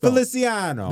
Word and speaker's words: Feliciano: That sounds like you are mispronouncing his name. – Feliciano. – There, that's better.